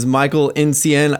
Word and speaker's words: Michael Nsien: His name is Michael Nsien.